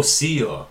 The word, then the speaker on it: Sia: Oh, S-I-A. – Oh, Sia.